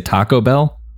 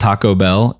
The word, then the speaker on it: Taco Bell: Used, I used to know somebody that used to put the emphasis uh, the emphasis on the wrong syllable on the wrong thing. – Yeah, they, they used to say Taco Bell not Taco Bell. It was Taco Bell.